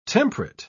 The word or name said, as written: temperate